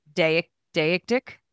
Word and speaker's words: deictic: Deictic. To me, it seems like deity, so I would pronounce it like deictic. D-E-I-C-T-I-C.